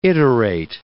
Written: iterate

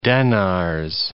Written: denars

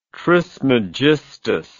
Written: trismegistus

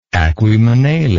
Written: aquaemanale

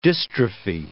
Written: dystrophy

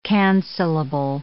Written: cancellable

